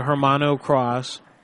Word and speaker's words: Hermano Cross: This is a Hermano Cross. – Hermano Cross? – Yes. – Could you spell that for me? – H-E-R mm-hmm.